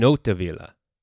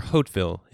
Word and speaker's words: Hauteville: It reached the home of Tancred, a relatively poor minor nobleman who lived in the castle in Hauteville, or Hauteville in English pronunciation, a small town on the Cotentin Peninsula.